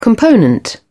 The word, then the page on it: component: British English